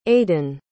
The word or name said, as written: aidyn